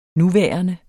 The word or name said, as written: nuværende